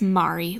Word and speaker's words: Mari: It's Mari, like starry.